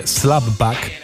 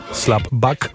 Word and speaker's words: Slapback: Nazywa się Slapback. Pisze się tak, jak słyszycie, czyli nie tak, jakby się wydawało, że batk, tylko samo k na końcu. Slapback.